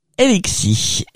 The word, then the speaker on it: LXSY: Le Roi, ça s'appelle Elixi, et ça s'écrit L-X-S-Y.